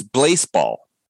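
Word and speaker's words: Blaseball: And it is Blazeball. – Oh. – It is spelled baseball with an L in the second letter.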